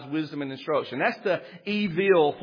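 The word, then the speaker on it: evil (אויל): There's another word for fool in Hebrew, and you could truly pronounce it as evil. You would spell it E-V-E-E-L, evil.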